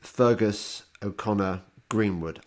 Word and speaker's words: Feargus O'Connor Greenwood: Feargus O'Connor Greenwood. I, have pronounced your name right, haven't I? Uh, you have. Yes, thank you.